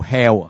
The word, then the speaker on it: HAL: 叫做 Hell 啊，啊即系个发音咧就同地狱差唔多噶，不过佢其实就 HAL